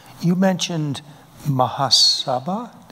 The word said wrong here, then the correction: Mahasabha: Did I pronounce it right? Ingrid? I didn't pronounce it right. – Yeah. – The accent's on the wrong part. Mahasabha? – Oh, Mahasabha. – Mahasabha. – Yes, yes.